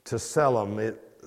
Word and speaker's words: tselem: It is tselem. It's spelled T-S-E-L-E-M, tselem.